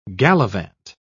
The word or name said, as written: gallivant